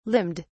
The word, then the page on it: limned: British English